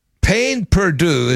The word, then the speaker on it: pain perdu: And the meaning of it is pain perdu is how to say it.